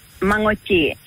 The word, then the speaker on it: Manoodji: It's called Manoodji, the tail end, the tail end sound is nga, nga, nga.